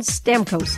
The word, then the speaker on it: Stamkos: The way, nope, we're just screaming for Steven Stamkos. Is that how you say his name, the hockey player? – Yeah.